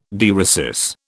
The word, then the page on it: dieresis: British English